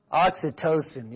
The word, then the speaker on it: oxytocin: Oxytocin, yeah, there you go, not oxycontin.